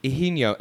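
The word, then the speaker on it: Higinio: Okay, his name is Ihinyo, and it's spelled H-I-G-I-N with the tilde above it, I-O.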